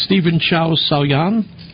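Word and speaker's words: Chow Sau-yan: Well, first of all, how do you pronounce his name? It's Stephen Chow Sao-Yan? – Yes, I think that's close enough.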